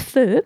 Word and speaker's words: feu: it's actually pronounced feu as reference to pot-au-feu.